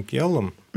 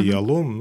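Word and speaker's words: Ялом: Ирвин Гиалом. – Угу. Ирвин Гиалом. – Да, или Гиалом, угу. Ну, по-разному произносят.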